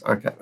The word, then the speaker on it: arXiv: If Multicast is the answer, what was the question, which is in, how do you pronounce this? Arxiv? I don't know how you pronounce that. – Archive? – Archive? – I think it's meant to be archive, yeah.